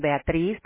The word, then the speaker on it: Beatriz: Okay, it's my name, Beatrice, which is spelled B-E-A. T S and Tom R I Z at Claremont Kitchens with an S .com. So it's BEATRIZ a zebra.